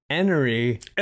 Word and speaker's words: Henery: Now, this is spelled Henry, H-E-N-E-R-Y, but pronounced Enery.